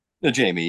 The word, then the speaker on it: Najemy: Najemy, yeah, that's Najemy, fine.